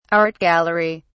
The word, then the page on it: art gallery: British English